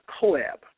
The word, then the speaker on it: COAAB: experience COAAB, which is, we pronounce it as COAB, for yourself.